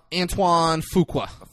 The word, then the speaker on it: Antoine Fuqua: Antoine Fuqua.